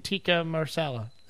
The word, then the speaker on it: tikka masala: Tikka marsala.